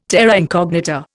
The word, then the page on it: terra incognita: Indian